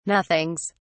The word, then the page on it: nothings: British English